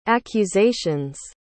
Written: accusation's

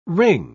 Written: wring

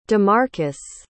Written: demarcus

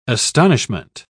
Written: astonishment

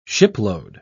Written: shipload

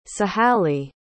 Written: sahalie

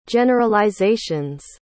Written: generalization's

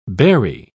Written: bury

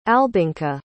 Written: albinka

